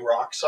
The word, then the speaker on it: roc: You know, people underestimate how big rocks are. – R-O-C. – Yeah, also spelled R-U-C, or in the case it pronounces rock.